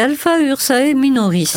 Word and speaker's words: Alpha Ursae Minoris: Ah, c'est Alpha Ursae Minoris.